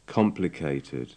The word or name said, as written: complicated